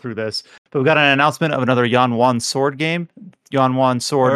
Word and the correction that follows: Xuan Yuan: Xuan Yuan Sword,